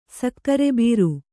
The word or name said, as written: ಸಕ್ಕರೆಬೀರು